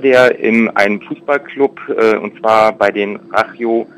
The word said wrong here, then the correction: Rayo Vallecano: ich entschuldige mein Spanisch, ich bin nicht so super in der Aussprache, äh, Rayo Vallecano, das ist der Fußballclub in der ersten Liga in Spanien.